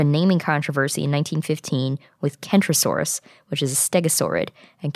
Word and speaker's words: Kentrosaurus: Centrosaurus is with a C, and Kentrosaurus is with a K, and also, as you can tell, they're pronounced differently.